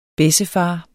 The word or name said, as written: bessefar